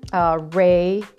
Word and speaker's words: Reiki: So the first one that I'm going to talk about is Reiki, and that's pronounced Reiki, and it's spelled R-E-I-K-I.